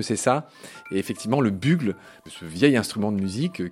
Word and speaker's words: Bugle: Et Bugle, c'est, alors, qui s'écrit bien B-U-G-L-E, qui se prononce Bugle en anglais, à la forme d'un corps de chasse, d'un clairon.